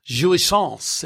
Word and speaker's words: jouissance: Tell us about jouissance.